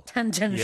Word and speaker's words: tangential: What is that word? How do you say it? – Tangential. – Tangential. – Yes.